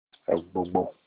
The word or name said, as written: egbogbo